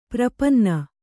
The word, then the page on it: ಪ್ರಪನ್ನೆ: prapanne